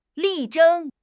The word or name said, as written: 力争